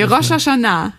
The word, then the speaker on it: Rosh Hashanah: Rosh Hashanah, habe ich das richtig ausgesprochen? – Nee, Rosh Hashanah. – Ja, Rosh Hashanah.